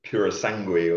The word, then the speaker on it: Purosangue: If I've said that right, I can never actually tell whether I've said it right. – I think that's how to pronounce it. Some people call it the Purosangue or something, but yeah, that is a hot car.